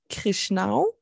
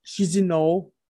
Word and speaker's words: Chișinău: Die Hauptstadt heißt Chișinău. Ich glaube, da spricht man so aus. – Die Allgemeinheit sagt, glaube ich, Chișinău. – Echt? Ach, da ist auch kein R drin. Chișinău. Für mich heißt das ja Chișinău.